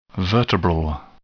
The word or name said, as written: vertebral